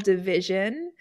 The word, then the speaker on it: DVSN: It's an R&B band called Division. – Oh, and I don't know who that is. I'll have to check it out. – Yeah, they actually spell it DVSN. DVSN.